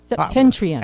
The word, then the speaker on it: Septentrion: S-E-P-T-E-N. – Oh, Septenian? Septentrion.